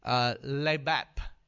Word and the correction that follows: levav: That's how you pronounce it? Levav. Okay. Lev or Levav.